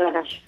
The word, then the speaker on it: ganache: Se escribe ganache, pero se dice ganache.